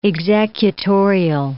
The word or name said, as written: executorial